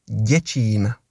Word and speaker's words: Děčín: You are pretty close, Annika, but the correct pronunciation of the name of the town where the hunger stone appeared is Děčín.